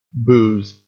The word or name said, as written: booze